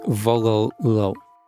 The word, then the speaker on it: Wololo: is pronounced like the Latin words vololo, which together translate to the sentence fragment, I want from him.